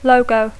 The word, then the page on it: logo: English